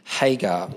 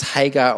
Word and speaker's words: Hagar: Now, Sarai, Abram's wife, had borne him no children, but she had an Egyptian slave named Hagar. Now, that can be pronounced Hagar or Hagar,